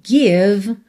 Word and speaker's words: give: Então, give, você não pronuncia a letra E, certo?